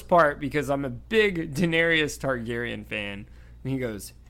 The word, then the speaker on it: Daenerys: Daenerys. – There's no I-U-S. It's Daenerys.